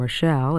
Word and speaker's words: Rochelle: My name is Rita, spelled R-I-T-A, and my last name, Rochelle, is spelled R-O-C-H-E-L-L-E.